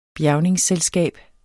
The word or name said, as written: bjergningsselskab